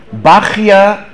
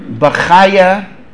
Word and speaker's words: Bachya: Bachya, Bachaya, or Bachai. There are three pronunciations.